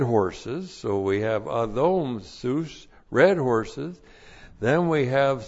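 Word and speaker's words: sus (סוס): So behind him, the Lord Jesus Christ, and then we have sus, S long U S, sus. Sus is the Hebrew word for horse.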